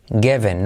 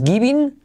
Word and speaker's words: given: Given, no es giving, sino given me a book.